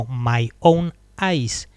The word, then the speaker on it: Mayonnaise: Años después, Billy Corgan explicaría el título fonéticamente, Mayonnaise, eh, traducido como mayonesa, en realidad se pronuncia como My Own Eyes, mis propios ojos.